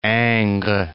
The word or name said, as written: Ingres